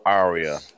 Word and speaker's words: Arya: Arya. – Arya. – That was Arya. – They like don't really pronounce the R half the time.